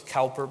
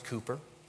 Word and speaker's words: Cowper: I know it looks Cowper, but it's actually pronounced Cooper. The British do that sometimes.